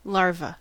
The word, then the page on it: larva: American English